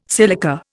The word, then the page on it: silica: Indian